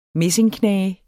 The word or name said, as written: messingknage